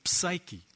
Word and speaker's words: psyche: The word mind here is the word psyche, if we want to pronounce it properly, psyche.